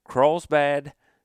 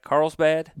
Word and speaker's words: Carlsbad: And again, from Carlsbad, I said that right? Carlsbad? – Yes.